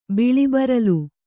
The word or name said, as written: ಬಿಳಿಬರಲು